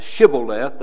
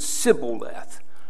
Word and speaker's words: Shibboleth: We started last week on the fact that here the Ephraimites could not say Shibboleth. They would pronounce it Sibboleth.